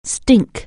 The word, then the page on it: stink: anglais